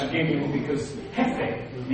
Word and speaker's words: Jefe: So instead, when I took Spanish in school years ago, my name, was pronounced the J. It's like an H. And so it's pronounced different. She said, "Okay, your Spanish name will be Jefe.